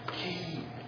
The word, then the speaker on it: chi: It says that it's German CH as in, let me see if I can say it right, Bach. – Yeah, Bach. – Okay, so can you say the CHI thing? – Yeah, which letter? – Key. – Yeah, it's just key.